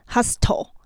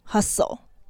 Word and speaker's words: hustle: 那个 hustle 这个字呢，后面是 s t l e，就像 castle 一样，那个 t 是不发音的，所以不是 hustle，它是 hustle。